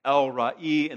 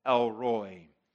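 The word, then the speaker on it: El Roi: And the two words in the original Hebrew, the two words in the original Hebrew is El Roy, E-L space R-O-I. In the Hebrew, it's pronounced El Ra'i. In the English, we would say El Roy.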